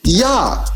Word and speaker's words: Jah: Translating the Hebrew's plural imperative form of you in English, yay, and the shortened form of Yahweh in Hebrew, Yah, praise ye Jehovah. So now, when you look at the word hallelujah, you can see that the J-A-H is pronounced Yah.